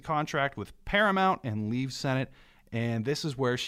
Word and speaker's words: Sennett: But during her time at Sennett Bathing Beauties, which is where she goes after Fox, and I think I'm pronouncing that right. It's it's spelled S E N N E T T.